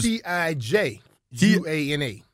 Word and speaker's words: Tijuana: It was T I J U A N A. Exactly. And it's pronounced Tijuana. How you spelled it?